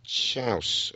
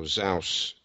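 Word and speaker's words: Xaus: Yeah, Ruben Chaus or Zaus, depending on which pronunciation you subscribe to.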